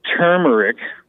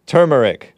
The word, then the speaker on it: turmeric: And I just wanted to say it's turmeric. – Turmeric. – Turmeric. – Okay. – Is how you say it. – Some people call it turmeric, and I wanted to call it turmeric. – Yeah, and that's fine, too, because you're using the first R in the word, right.